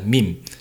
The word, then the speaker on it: meme: viết tắt là meme đó mà đọc meme là sai nha phát âm chuẩn tiếng anh phải đọc là meme ha meme giống tiếng việt mình là im im im meme vậy đó ừ.